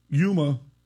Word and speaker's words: Yuma: Yuma, Arizona.